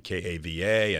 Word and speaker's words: Kava: So there was a lot of uh, lava stone lying around, and the station's name was Kava Country, K A V A, and the lava rhymes with Kava.